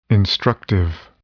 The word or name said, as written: instructive